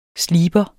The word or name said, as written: sliber